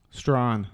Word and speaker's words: Strahan: Strawn. – Strawn, like S-T-R-O-N? – S-T-R-A-H-A-N.